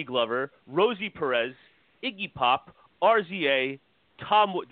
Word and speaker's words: RZA: Yeah, RZA.